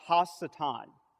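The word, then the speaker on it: Ha-Satan (הַשָּׂטָן): It's literally pronounced Ha-Satan, right? It means the accuser.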